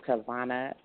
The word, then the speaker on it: Tivona: You can also find me up, up under Tivona Elliot Clark.